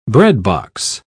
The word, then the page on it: breadbox: British English